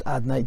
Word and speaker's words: Adnay: to indicate that it's written Hashem and it's pronounced Adnay.